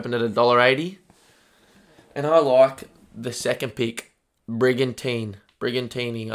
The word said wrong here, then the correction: Brigantine: I don't actually know how to say that one. Let's have a look. Tell me how to say it. Sorry, it's going to tell me how to say it. Come on, Darren. Brigantine. That was hard, was it?